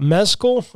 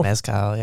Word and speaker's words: mezcal: Mezcal, am I saying that right? – Mezcal, yeah.